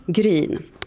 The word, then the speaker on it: Gryn: Henry, uttalar jag ditt efternamn rätt om jag säger Grun? – Gryn.